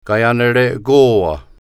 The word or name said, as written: Kaianerekowa